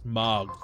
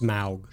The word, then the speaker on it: Smaug: It's going to go to this smog. – Pronounced smaug.